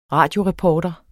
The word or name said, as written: radioreporter